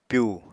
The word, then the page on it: pyu: Apatani